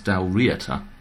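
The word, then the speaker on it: Dál Riata: Came pretty much from nowhere to rule over both Dalriata, or somebody pointed out to me actually on the WordPress blog, it should perhaps be pronounced Dalriata. – Why? – Because you emphasise the first syllable, apparently, in Scottish. So if you have Dal and then Riata, it would be Dalriata.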